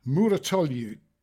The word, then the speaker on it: Mouratoglou: So you find yourself watching, I don't know whether you did this, Andy, but you do find yourself watching Patrick Mouratoglou, who's Serena Williams's coach. I think that's how you pronounce it. Mouratoglou. Yeah, that'll be that.